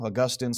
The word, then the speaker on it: Augustine: Augustine of Hippo, Augustine, sometimes people say it like that,